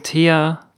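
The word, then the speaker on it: Théa: Ich glaube, so spricht eins Thea auf Französisch aus. Stellt euch einfach den Namen Thea mit einem Akzent in die aufsteigende Richtung über dem E vor. So wie das FranzösInnen aussprechen würden.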